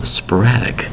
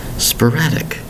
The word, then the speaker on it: sporadic: Today's word is sporadic. Sporadic. Three syllables for the word sporadic. S P O R A D I C. That's right, there's no T there.